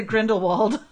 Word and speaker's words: Grindelwald: No, maybe I said that wrong. – Grindelwald? Yeah. – Is it Grindelwald? Oh, of course.